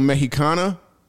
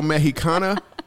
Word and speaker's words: Mexicana: You may be wondering, what can you learn from a neurological doctor, a little Mexicana? Did I say it right? – You did. I'm so impressed A little Mexicana and a comedic thought leader.